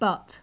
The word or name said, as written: but